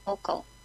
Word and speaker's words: Volkel: The first one's Leeuwarden, and the second one's Volkel.